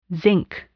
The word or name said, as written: zinc